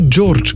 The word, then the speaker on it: George: Ime George se sklanja George, Georgia, Georgiu, Georgia pri Georgiu z Georgem. Samoglasnik E v pisni obliki obstaja, a se ne izgovori.